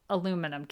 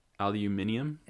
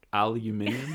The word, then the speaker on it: aluminium: There's still things, aluminum, can you say it? – Aluminium? – Is how they say it here. Say it again. – Aluminium? – I think you said it right.